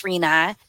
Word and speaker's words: Rena: And one of the reasons, because when I answer the phone, I'll say, this is Rena. People will say, hey, Serena. But when my name is Rena, people catch my name.